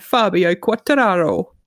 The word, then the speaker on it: Fabio Quartararo: So we've got Maverick Vinales and Fabio Quartararo.